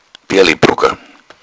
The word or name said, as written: brocken